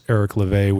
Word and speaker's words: Eric Levai: My name is Eric LeVay, which is spelled E-R-I-C-L-E-V-A-I.